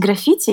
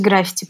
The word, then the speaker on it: граффити: Правильно говорить граффити, я посмотрела на Грамота.ру, но я сегодня буду говорить граффити, потому что граффити мне просто режет мозг. – Так правильно говорить граффити, но я буду говорить граффити.